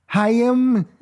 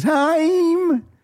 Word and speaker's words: Haim: You pronounce it Haim. Haim. I always want to say it like Jerry Lewis. Haim.